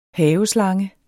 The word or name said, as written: haveslange